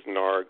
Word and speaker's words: Naarg: Yeah, everybody knows in Telluride language, the double A is an R sound. No. Yeah, okay. – The character's name is spelled N-A-A-R-G. Yeah, so I did a few episodes as Narg, who's a Telluride ambassador.